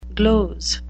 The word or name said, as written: gloze